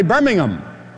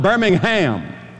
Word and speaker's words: Birmingham: And they would laugh every time, and suddenly I realized the reason they were laughing was because over there they say Birmingham, not Birmingham. And I was putting the emphasis on the ham.